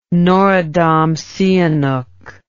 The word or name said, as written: norodom sihanouk